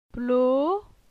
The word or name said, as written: Plo